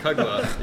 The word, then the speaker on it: Kugla: I'm going to mispronounce it, but it looks like it's called Kugla. – Kugla, yeah.